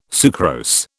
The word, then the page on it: sucrose: British English